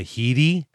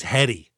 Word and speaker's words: Headey: David, on a podcast ad, I heard her name pronounced as Lena Headey, and I looked it up, and I think it is Headey. I think we are correct in saying Headey.